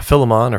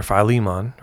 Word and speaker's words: Philemon: Later in Philemon or Philemon, right? Depending on how you want to pronounce it.